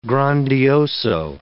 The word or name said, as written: grandioso